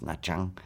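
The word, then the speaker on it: Nha Trang: Il était à Na, et puis euh, ça s'écrit Natrang, mais ça se prononce Nachang, je pense.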